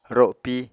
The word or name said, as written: ropi